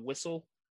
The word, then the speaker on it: Whissell: Then you got Anouk Whistle, but spelled like WHISSELL.